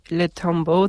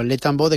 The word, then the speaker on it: Le Tombeau: Bueno, lo has pronunciado bastante bien. – Vale. – Yo tampoco soy francés, pero Le Tombeau de Claude Debussy.